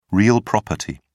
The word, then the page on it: real property: British English